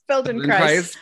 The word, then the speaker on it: Feldenkrais: Feldenkrais practitioner.